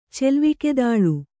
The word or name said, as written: ಚೆಲ್ವಿಕೆದಾಳು